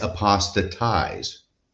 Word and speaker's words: apostatize: I think Mormons are about the only person who use the verb form of apostasy, and more than 50% of the time they mispronounce it. So it's apostatize.